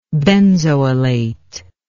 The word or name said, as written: benzoylate